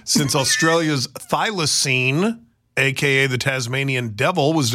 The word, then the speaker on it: thylacine: It has been decades since Australia's thylacine, and I said Tasmanian devil.